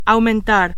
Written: aumentar

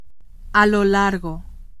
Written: a lo largo

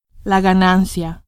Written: ganancia

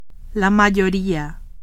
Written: mayoria

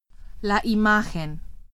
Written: imagen

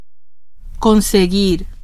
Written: conseguir

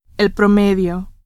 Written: promedio

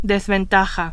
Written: desventaja

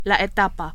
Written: etapa

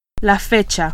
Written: fecha

